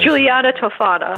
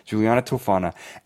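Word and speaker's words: Giulia Tofana: And she was like you're going to say the name correctly. I always butcher names. I apologize. – Giuliana Toffana. – That's it. That's what I almost said. Giuliana Toffana.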